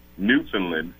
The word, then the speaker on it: Newfoundland: We are actually, at the end of March, going to, and I just learned how to say it correctly, Newfoundland to play a team in the NBL Canada.